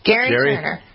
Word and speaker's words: Gerry: Gary Turner. – Why isn't it, why, Gary is spelled G-A-R-Y. This guy's name is spelled G-E-R-R-Y. – Yeah, well, J-A-I is typically Jai, but here we are.